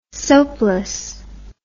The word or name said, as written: soapless